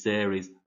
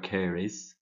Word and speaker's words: Ceres: Demeter is Ceres or Ceres, depending if you have a hard or soft C sound.